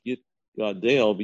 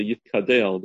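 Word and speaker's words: yit gadel (יתגדל): Like sometimes people say yit kadel, but it's yit gadel, yit kadesh.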